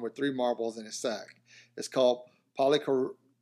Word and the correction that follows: polyorchidism: How do you say that? – Polyorchidism. – polyorchidism. See, that's where I was screwing up. I thought it was orchid, but I was like, no, that can't be it.